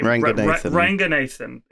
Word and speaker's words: Ranganathan: Ranganathan.